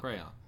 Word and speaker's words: crayon: Everyone says crayon. – Crayon. Crayon. It's two syllables.